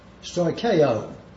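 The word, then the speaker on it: stoicheo (στοιχέω): The second Greek word is stoicheo, which is spelled S-T-O-I-C-H-E-O, stoicheo.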